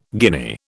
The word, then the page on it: guinea: British English